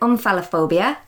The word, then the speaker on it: omphalophobia: And I was saying it was omphalophobia, and then Colin said to be careful pronouncing it because that could mean the fear of penises.